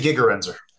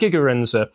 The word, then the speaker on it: Gigerenzer: Gerenz. Could you pronounce that for me again, please? – I mean, I could be missing it too, but yeah. I say Gigerenzer. – Gerd Gigerenzer,